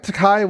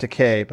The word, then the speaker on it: Takei: I thought Takai was Chinese for expensive or something like that, and it's actually pronounced Takei, but whatever.